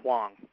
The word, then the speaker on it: Hoang: And it's actually spelled H-O-A-N-G, but I believe it's pronounced Huang.